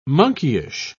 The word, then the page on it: monkeyish: British English